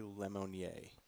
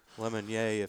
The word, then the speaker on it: Lemonnier: I-E-R. If I'm French, it's Lemonnier. If it's not, it's Lemonnier. I don't know.